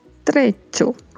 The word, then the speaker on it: trecho: Lembrando que se fala com o som de uma palavra que estamos habituados a falar, que é tchau. Então pronunciamos dicho, hecho, trecho.